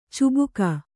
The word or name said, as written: ಚುಬುಕು